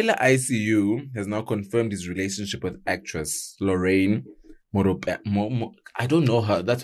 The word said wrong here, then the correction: Moropa: Just please pronounce her surname and name properly. – Moropa. – I read Moropa.